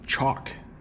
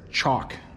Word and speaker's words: chalk: We call this stuff chalk in English. I'll spell it up there, chalk. It has an L in it. It doesn't have an L sound, but it has the letter L in there.